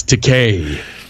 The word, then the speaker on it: Takei: It's Takei. They always pronounce it wrong.